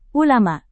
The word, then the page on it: ulama: indonesian